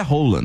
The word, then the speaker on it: Haaland: O Haaland. – Nossa, gente. – Ele é altão, né? – Que é aquilo, gente? Hein? Branquinho. – A pronúncia oficial do nome dele é Rola, né? – É, então, eu fiquei sabendo, fiquei totalmente constrangida.